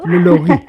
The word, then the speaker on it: Lalaurie: ¿Quién era esta buena mujer, Delphine Lalaurie? – Pues bueno, qué bien pronunciado, ¿eh? Todo. – Lalaurie.